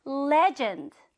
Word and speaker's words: legend: 레전드 이렇게 표현하기도 하지만 영어로는 legend. 악센트가 앞에.